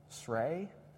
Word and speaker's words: Srey: One, I'm going to butcher the name, it's S-R-E-Y, Srey, Srey Mom.